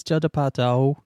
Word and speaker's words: Judd Apatow: Every time you say his name like that, I think it's some African guy. – What? – Judd Apatow. It's Judd Apatow. Judd Apatow. Right? – Judd Apatow. – Is it Apatow or Apatow? – Jew Apatow.